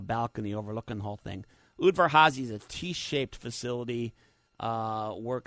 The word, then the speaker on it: Udvar-Hazy: So it's Udvar-Hazy, in case you want to learn how to pronounce it, Udvar-Hazy.